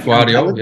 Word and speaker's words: Gvardiol: Excuse the pronunciation. Croatia's Josko Gvardiol, is that correct? Is a great Gvardiol, yeah.